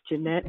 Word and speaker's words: Jeanette: It's Jeanette Espinoza.